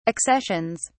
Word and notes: accessions: British English

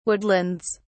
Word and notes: woodlands: British English

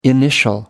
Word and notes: initial: American English

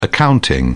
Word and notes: accounting: British English

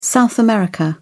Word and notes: South America: British English

